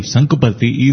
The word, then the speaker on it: Sankuparthi: And he said that Sankuparthi, the name Sankuparthi is rhyming with Puttaparthi, and Anantagiri Hills is somewhat similar to the Anantapur district.